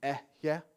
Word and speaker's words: Ehyeh: So the root word, actually, the way it would have been pronounced is Ehyeh in the original Hebrew.